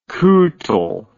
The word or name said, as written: cuittle